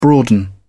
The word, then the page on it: broaden: British English